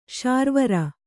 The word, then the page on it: ಶಾರ್ವರಿ: Kannada